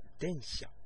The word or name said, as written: densha